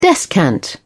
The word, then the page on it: descant: British English